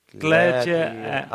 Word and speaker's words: glädje: Glädje alltid i Herren. – En gång vill jag säga glädje är. I don't know if I'm saying it right. – Yeah, that's good.